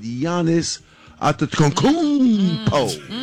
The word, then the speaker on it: Giannis Antetokounmpo: And breaking news, my man from Milwaukee Bucks has ended their relationship with Giannis Antetokounmpo. Did I say it right, Trey? – Just about. You was right there.